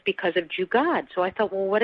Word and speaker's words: jugaad: Can you explain, and first perhaps pronounce that word, and how's that defined? – Sure. So the word is jugaad.